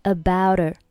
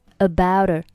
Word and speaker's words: about her: Julie and 末尾的可以不用发音，后面的 about 和 her 可以连读成 abouter。abouter, abouter。注意这里，her 开头 h 它对应的这个音呢是可以不用发出声音的。那我们再和 about 连起来读，就是 abouter, abouter, abouter。注意 about 末尾在这里要浊化一下。Abouter with Jack.